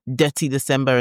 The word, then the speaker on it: Detty December: But, the first thing to know is I think Detty December is, you know, Detty, D-E-T-T-Y, right? So for the people who always like, you know, feel like, oh, am I pronouncing it right? Is this an accent thing? No, it is Detty December in that sense.